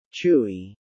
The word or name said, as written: Chewy